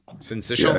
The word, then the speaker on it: syncytial: Syncytial? – Yeah, syncytial.